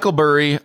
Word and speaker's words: Burry: Am I saying that right? Is it Burry? – Yeah, Michael Burry.